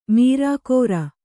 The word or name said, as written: ಮಾರ್ಕರೆ